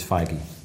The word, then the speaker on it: Feige: Feige, based on the spelling and the pronunciation of the word beige, I think the confusion is understood. But Alan is right. It is Feige.